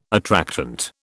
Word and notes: attractant: British English

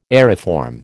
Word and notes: aeriform: American English

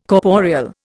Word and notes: corporeal: Indian

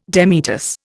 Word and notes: demitasse: Indian

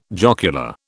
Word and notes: jocular: British English

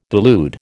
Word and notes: delude: American English

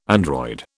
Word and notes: android: British English

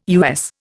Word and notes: US: Indian

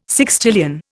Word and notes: sextillion: Indian

